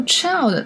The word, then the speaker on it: Childe: So child 它的发音虽然和儿童的那个 child 是一样的，但是拼写上在最后是多了一个 e。